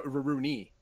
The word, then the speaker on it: Rurouni: You're supposed to roll both those R's. – Well, it's like an LR sound. – Yeah. – Rurouni Kenshin. – No, Rurouni. – Rurouni Kenshin.